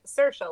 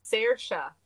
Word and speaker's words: Saoirse: I also saw an interview with her where she says Saoirse, and she introduces her name as like Saoirse, like inertia. And then I guess some Irish people are like, "You're saying your name wrong. It's Saoirse Ronan."